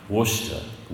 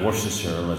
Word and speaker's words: Worcester: But I was warned that you will feel like you'll be a stranger if you can't pronounce the name of one town, Worcester, and not Worcestershire, as I first said when I got there. No, it's Worcester.